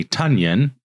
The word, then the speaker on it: Tonyan: Next up, we have Robert Tonyan. Tonyan. Everybody's been saying it wrong.